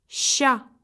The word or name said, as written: sch